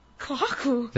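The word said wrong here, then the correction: kahakō: Did I say it wrong? – Yeah. – Oh, what? – Kahako.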